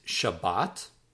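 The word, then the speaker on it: Shabbat (שבת): The next word for Sabbath, number 23, is Shabbat.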